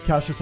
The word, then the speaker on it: Cassius: Cassius? Is that how it's pronounced? – Yeah. – Like Cassius Ali? – Yes.